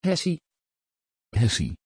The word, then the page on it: hettie: Dutch